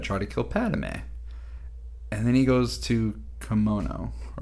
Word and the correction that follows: Kamino: Am I saying that right? Kamino, yeah.